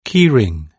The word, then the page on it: key ring: British English